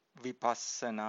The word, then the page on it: vipassana: Pali